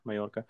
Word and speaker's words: Mallorca: Is that how you pronounce it? – Mallorca.